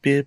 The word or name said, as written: bib